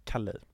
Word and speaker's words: Kali: Ja, är det så man uttalar det? – Ja. – Jag har varit jätteosäker. – Nej, Kali.